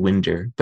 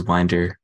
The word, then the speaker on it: Winder: So we pronounce it Winder, but historically I think it was Winder because my ancestors at some point worked with clocks.